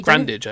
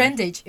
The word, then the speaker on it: Grandage: And the show was directed by Michael Grandage. The book by Grandage, I would say. – Grandage, okay. No, sorry, not. That's my Portuguese butchering it.